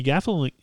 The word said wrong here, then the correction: Gaffigan: Well, what's his last name? The most famous comedian in the world. I can't even pronounce his last name. Jim Gaffigan? Yeah, that sounds right.